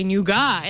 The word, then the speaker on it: nougat: And he's like, "Oh, that's not how you say it. It's you mean nougat?" – Oh, whoa.